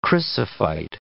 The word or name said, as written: chrysophyte